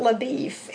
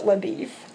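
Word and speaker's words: LaBoeuf: And like I said a while ago, it looks like it should be pronounced, here it is, LABEOUF. – Okay. – But in the movie, they pronounced it LaBeef.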